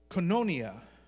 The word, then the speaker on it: koinonia: And it's a word that's pronounced koinonia, which means more than just hanging out.